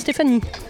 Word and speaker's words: Stéphanie: Je m'appelle Stéphanie. – Comment ça s'écrit ? – Stéphanie ? – Oui. – S-T-E-P-H-A-N-I-E.